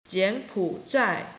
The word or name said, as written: jian3pu3zhai4